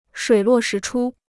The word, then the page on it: 水落石出: shuǐ luò shí chū